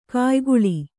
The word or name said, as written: ಕಾಯಗೊಳ್